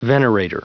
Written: venerator

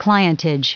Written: clientage

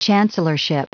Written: chancellorship

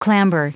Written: clamber

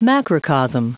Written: macrocosm

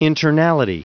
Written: internality

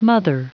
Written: mother

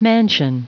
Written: mansion

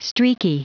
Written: streaky

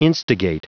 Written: instigate

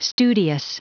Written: studious

